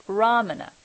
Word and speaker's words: Ramana: Yeah, it's Ramana.